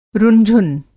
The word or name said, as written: रुन-झुन